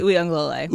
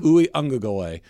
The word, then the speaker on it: Uiagalelei: DJ Uiagalelei. – Uiagalelei.